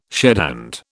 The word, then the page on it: shedhand: British English